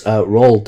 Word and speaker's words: Roald: It's Roald Dahl over here, like Roll as in...